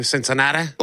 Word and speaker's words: Cincinnati: Cincinnati? – Oh, yeah.